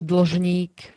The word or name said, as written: dlžník